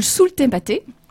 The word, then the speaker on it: Sous le thématé: Alors, on écoute maintenant une chanson qui s'intitule « Sous le thématé ». Si j'ai bien prononcé, Edouard, c'est ça? – Ouais, exactement.